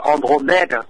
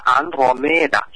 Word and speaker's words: Andromeda: Cái nàng con gái bị buộc vào trong vách đá tên là Andromeda, tiếng Pháp là Andromeda, còn tiếng Hy Lạp nghe là Andromeda.